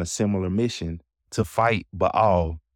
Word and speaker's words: Baal: Now, you may hear me saying Baal. That's the correct Hebrew pronunciation of it. But you may hear most people say Baal, and we're not nitpicky people. So either of those are fine if you want to be technical and say it the right way, or if you just want to say Baal.